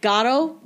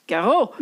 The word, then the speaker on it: Garo: And my dad's name was Garo, and so I mean I say it like an English person. I don't say it like an Armenian person. How do Armenian how do Armenian? Oh God, I'm, I don't know. Like Garo, like they roll the R. The A is more of like a vowel.